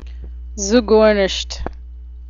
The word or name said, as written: zuggornisht